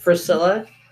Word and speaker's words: Frisella: It's called #75hard by Andy Frisella. Did I butcher that? Frisella. Frisella, Andy Frisella.